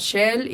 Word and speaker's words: Zhelle: Estoy más activa en Instagram, así que me pueden buscar como Shell. Igual aquí va a aparecer el nombre, así como se escribe, porque luego hay dudas.